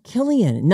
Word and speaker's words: Killian: Yeah, Killian. Not Cillian with a C. Killian pronounced with a hard K sound.